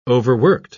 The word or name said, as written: overworked